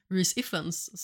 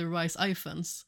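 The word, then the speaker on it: Rhys Ifans: Eh, Otto Hightower naturligtvis spelad av eh, Reese Iffens, som jag har fått reda på att det heter, och inte Rice Iffens. Eh, vilket jag har uttalat eh, hela tiden. Nu uttalar jag det förmodligen inte jättebra, men det är lite mer åt det hållet mm.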